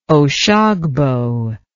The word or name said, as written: Oshogbo